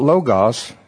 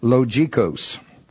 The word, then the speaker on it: logikos: It's not logos, it's logikos. And you spell it L-O-G-I-K-O-S.